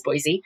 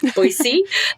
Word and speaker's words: Boise: So natively, because I'm not originally from here, I thought the name of the place was Boise, but I was given that insider information. I was able not only to use the correct pronunciation, right? Boise, the insider version, but also to mention it in the way that I explained the way that my research works on sounds.